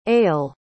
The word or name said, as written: ayele